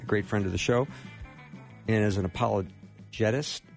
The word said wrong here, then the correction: apologist: did I say that right? – Apologist. – Apologist. Okay. Thank you for correcting me.